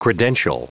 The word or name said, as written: credential